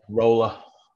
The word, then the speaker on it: ROLLA: Is that the right way to say it? ROLLA. – ROLLA.